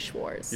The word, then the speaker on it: Schwarz: I'm Americanizing a German name, but I say Schwarz.